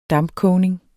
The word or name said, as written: dampkogning